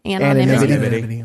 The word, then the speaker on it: anonymity: Is am I saying that right? – Anonymity. – Anonymity. What? – It's a C, anonymity. – Anonymity. – It's, yeah, that's right.